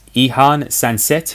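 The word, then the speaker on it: Oihan Sancet: It's Ihan Sanset.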